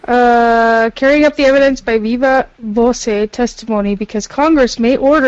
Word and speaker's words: viva voce: How do you say it? You say voce. – Yeah, so by voice. – Viva voce. – So viva voce. Voce, voca. Viva. It's by voice.